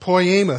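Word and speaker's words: poiema: That word workmanship in Ephesians 2:10 is the Greek word poema. Sounds like the word poem, doesn't it? Kind of poema, poem.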